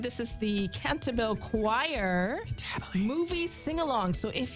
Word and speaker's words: Cantabile: Pronounced Cantabile. – Oh, what? What did I say? – You said Cantabile or something. – Okay, so how is Cantabile? – Yeah, it's like Latin for singing all together.